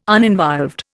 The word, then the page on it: uninvolved: Indian